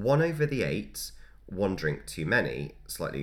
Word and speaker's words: ait: However, at this pub, at one over the eight isn't spelt like the number, it's spelt A-I-T. Mm-hmm. And that's because an eight, in that sense, is a small island, particularly in the Thames.